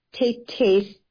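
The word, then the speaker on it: Tay Tay's: And he is sharing with us some stories from a collection of the book titled Tete's Tales. So I'm saying it the way you see the title. It's spelled T-A-Y-T-A-Y apostrophe S, Tales.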